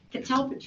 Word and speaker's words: catalpa: The request includes removal of a mature, how do you pronounce that tree? – Catalpa. – Thank you. Catalpa tree in the park road to allow the installation of the new driveway approach.